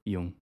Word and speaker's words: Jung: Carl, how do you say his last name? – Jung.